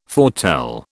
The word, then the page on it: foretell: British English